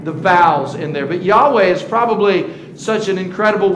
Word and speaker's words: Yahweh: Uh, the name of God would be pronounced Yahweh.